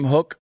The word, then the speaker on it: Houck: Hulk, H-O-U-C-K.